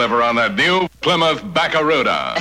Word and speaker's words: Barracuda: Hey, man, the name of the Plymouth Fastback is the Barracuda. – I can't pronounce Barracuda. – Yeah, well, look, man, try this. Say back. – Back. – Ra. – Ra. – Coo. – Coo. – Da. – Da.